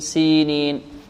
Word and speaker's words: سينين: هذا المبتدئين، المبتدئين، هذا المبتدئين والذين يخطئون في هذه الكلمة، وإلا في المتمرس ينطق هكذا وطور سينين، سينين، لكن المبتدئ يركز عليها ويركز على أن هناك ياء مدية بعد السين، هكذا وطور سينين، سينين، سينين، والتين والزيتون وطور سينين، وهذا البلد الأمين، وهذا، الذال هنا ماذا؟